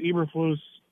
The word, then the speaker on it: Eberflus: Eberflus, I don't even know how to pronounce it.